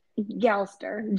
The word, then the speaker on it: Galster: How do you say and spell your last name? I don't want to mess it up. I'm sorry. – Galster. G-A-L-S-T-E-R. Yeah.